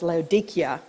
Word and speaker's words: Laodicea: And we're at the final one here, Laodicea, known by the Turks as Laodikia, in the correct kind of pronunciation of it.